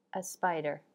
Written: a spider

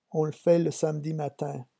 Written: on le fait

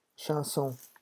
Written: chanson

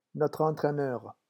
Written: notre entraîneur

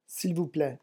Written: sil vous plait

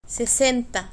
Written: sesenta

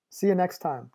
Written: see you next time

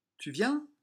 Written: Tu viens

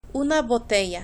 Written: una botella

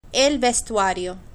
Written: el vestuario

